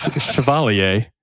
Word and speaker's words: Chevalier: It is Chevalier. – I'm sorry. Is that the official pronunciation? – That is the correct pronunciation.